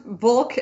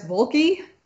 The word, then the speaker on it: Volk: He pronounced Volk as Volky when he was first doing narration. And I was like, whoa, whoa, whoa. – You're like, that's what. – Yeah, not Volky.